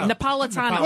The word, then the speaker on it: Napolitano: And now we have Judge Andrew Napolitano. Did I say it right? – Napolitano.